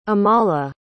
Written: amala

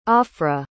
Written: ophrah

